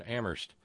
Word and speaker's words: Amherst: Albertan Canadian correspondent Lauren, and thank you very kindly to Terry in Amherst, where only the H is silent.